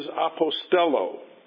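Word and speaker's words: apostello (ἀποστέλλω): So as the Father sent me, the word is apostello.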